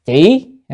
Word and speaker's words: chỉ: chữ đảng này nên đọc là chỉ,